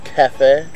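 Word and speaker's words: Cafe (кафе): Cafe? – Cafe is how you say coffee in Bulgarian.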